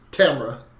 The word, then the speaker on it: Tamara: I think you're giving it too many syllables. I'm pretty sure it's just Tamara.